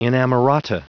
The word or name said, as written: inamorata